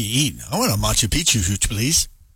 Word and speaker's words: Machu Picchu: It reaches all seven continents, and you get to see places like the Taj Mahal, the Great Wall of China, the Colosseum, Machu Picchu. I think that's how you say it. – Machu Picchu.